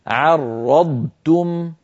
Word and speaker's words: عرضتم: علينا أن ننتبه ألا تتحول الضاد إلى طاء وأيضا التاء التي بعد الضاد تتحول إلى طاء فينطق بعض إخواننا عرضتم عرضتم أو عرضتم يعني يحول الضاد إلى طاء لابد أن تبقى الضاد ضاد والتاء تاء هكذا عرضتم عرض ضاد عرضتم